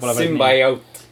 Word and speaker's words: Symbiote: jah, mis isegi mis ta oli, Merriam-Webster website või midagi Twitteris või kuskil mingi sait jagas seda, andis mõista, et ei, seda hääldati niimoodi Symbiote.